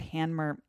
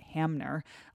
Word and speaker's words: Hanmer: If the 90-miler canoe race is a marathon, the Willard Hanmer is a sprint. And yes, it's Hanmer, not Hamner. A lot of people mispronounce it as if the M comes before the N, which it doesn't.